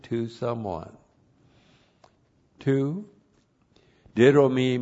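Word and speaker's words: Didomi (δίδωμι): didomi plus the accusative singular of gnome. The conjunction chi is a connective chi, correctly translated, and. And then we have our friend didomi. D-I-D long O-M-I. Didomi.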